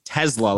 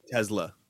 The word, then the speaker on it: Tesla: I pronounce it Tesla, like T-E-Z-Z-L-A. – Oh, you're a piece of shit.